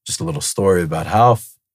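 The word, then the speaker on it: Rolls: For anyone who doesn't know what Half is, it's spelled with an R if you want to look it up. – All right. It's spelled with an R and they spell it, right? – Because all in Portuguese, every R is pronounced like an H.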